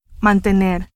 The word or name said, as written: mantener